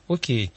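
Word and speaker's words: OK: «Ок», окей. Но «ок», попав в Англию, в Америку, стал произноситься по-английски. Буква «к» стала произноситься «кей». И они пишут четко «ок». Две буковки, а произносится «окей».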